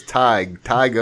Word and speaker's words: Tadhg: His name is spelled T-A-D-H-G. And he was cracking up laughing. He said, man, this is hilarious. So he sent me a pronunciation for his name. It's Tig O'Connor.